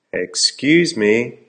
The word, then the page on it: excuse me: American English